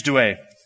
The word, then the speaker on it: Douay: Now, just in case I pronounced that wrong, Paul, how would you say that? Rhymes Douay? Reams. Did I get Douay right? Okay, Reams Douay.